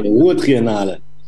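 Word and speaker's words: Ruhrtriennale: Ruhrtriennal.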